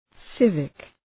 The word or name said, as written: civic